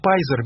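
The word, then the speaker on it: Pizer: По-английски его фамилия произносится как Пайзер, видимо, он Питцер. P-I-Z, да?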